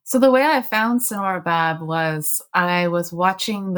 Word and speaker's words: Sanora: I'm kind of curious to know, before we talk about Sonora herself, whose name is spelled S-A-N-O-R-A, not like the Sonora Desert,